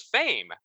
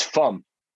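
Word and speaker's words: Faim: I always thought it was Fame. How do you pronounce that? It's Fum. It's French for hunger.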